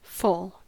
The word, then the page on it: foal: American English